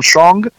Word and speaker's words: Shang: You changed your name from Shong to Sean as a way of trying to hide. And I thought, oh, that's an easy way to remember how it's properly pronounced because right. it's just like Sean.